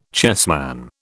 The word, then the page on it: chessman: British English